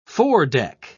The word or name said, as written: foredeck